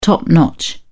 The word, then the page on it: top-notch: British English